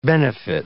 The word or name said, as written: benefit